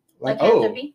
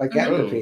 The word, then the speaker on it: lycanthropy: got in touch with our inner, how do we say it? Lycanthropy? – Lycanthropy. – Mm-hmm. Lycanthropy, mm-hmm. Yes.